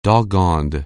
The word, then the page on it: doggoned: anglais